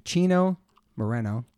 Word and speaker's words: Chino Moreno: I have a very special guest here today in my kitchen, Mr. Chino Moreno.